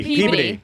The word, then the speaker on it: Peabody: It looks like Peabody, but it's Peabody. – But it's pronounced like garbage.